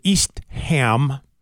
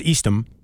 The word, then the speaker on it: East Ham: Yeah, and my folks rented a place in East Ham, which I found out is the way you pronounce it. I thought it was Eastham out on Cape Cod. It's East Ham, according to the locals there who corrected us.